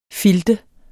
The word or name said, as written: filter